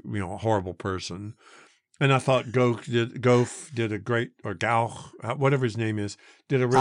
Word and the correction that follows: Gough: I am reading now that it is pronounced Guff.